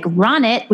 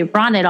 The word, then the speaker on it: Ronit: which is Ronit Plank. So Ronit is spelled R-O-N-I-T, like Ronit, which is why people say Ronit all the time, but it's Ronit. And then it's Plank, P-L-A-N-K, like the yoga pose that I don't do enough of.